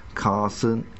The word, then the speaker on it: carcinogen: The International Agency for Research on Cancer lists beryllium and beryllium compounds as Category 1 carcinogen. – Carcinogen, yeah. – Carcinogen, yeah. – The last but one syllable every time.